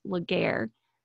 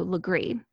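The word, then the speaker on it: Legare: Now, if you look anything up on this, their last name is actually spelled and looks like it is pronounced Laguerre, but it has a little bit of a French pronunciation, so Legree.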